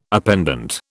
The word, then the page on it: appendant: British English